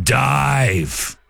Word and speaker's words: DIIV: So we bust out new music from Dive. The one that's spelled D-I-I-V.